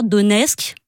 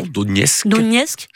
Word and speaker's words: Donetsk: Donetsk je l'ai mal prononcé. Donetsk.